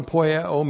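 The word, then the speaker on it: poieo (ποιέω): P-O-I-E, long O, poieo. And first of all, one, poieo means to do.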